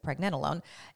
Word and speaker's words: pregnenolone: So pregnenolone or pregnenolone, tomato, tomato, you know, however you want to pronounce that, but I pronounce it pregnenolone, is it's secreted from the adrenal glands, and that can actually drop dramatically.